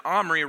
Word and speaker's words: Omri: In verse 25, we learn of Omri, and Omri rhymes with Henri, and we all know what Henri people do, not good things.